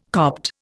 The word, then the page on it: Copt: Indian